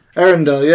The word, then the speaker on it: Arendelle: Arendelle, yeah, there you go.